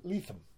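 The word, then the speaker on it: Lethem: I met him at Claremont at a book thing for Mr. Letham, Jonathan Letham. That's how you pronounce it.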